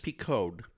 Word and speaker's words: Picaud: Picaud. P-I-C-A-U-D.